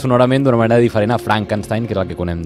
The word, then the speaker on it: Frankenstein: Doctor Frankenstein? Frankenstein. ¿Me toma el pelo? No. Se pronuncia Frankenstein.